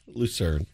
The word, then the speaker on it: Lucerne: Lucrine, by the way. – Lucrine? – Lucerne. – Lucerne? That's how it's spelled. Lucerne.